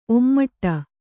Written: ಒಮ್ಮಡಿ

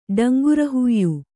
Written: ಡಂಗುರಹುಯ್ಯು

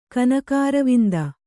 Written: ಕನಕಾರವಿಂದ

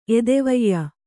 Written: ಎದೆವಯ್ಯ

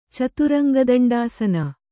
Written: ಚತುರಂಗದಂಡಾಸನ

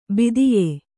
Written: ಭಿದ್ಯ

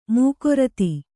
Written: ಮುಖರಿತ

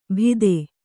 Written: ಭೀತೆ

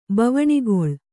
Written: ಬವಣಿಗೊಳು

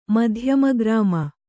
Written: ಮಧ್ಯಮಗ್ರಾಮ

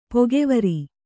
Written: ಪಕ್ಕುವಾರು

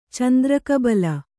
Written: ಚಂದ್ರಕಬಳ

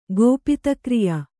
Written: ಗೋಪಿತಕ್ರಿಯ

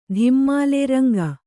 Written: ಧಿಮ್ಮಾಲೆ ರಂಗ

